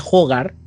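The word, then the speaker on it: Hårga: porque se escribe Hagar, pero la A tiene unos signos raros que se pronuncia Hogar.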